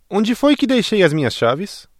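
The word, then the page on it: chave: Brazilian Portuguese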